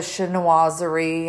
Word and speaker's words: chinoiserie: Okay, so let's go back to the chinoiserie.